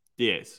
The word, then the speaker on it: diez: But how do you say ten? Diez. Diez. So Diez and Diaz.